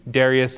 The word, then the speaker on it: Darius: Darius, depending on how you like to pronounce it, I go with the Darius as in Darius Rucker.